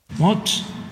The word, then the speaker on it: Musk: Haga de cuenta que va a decir mosca y le quita la A. – Mosk.